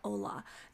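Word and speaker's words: Hola: You had me at Ola. No, that's how you say it.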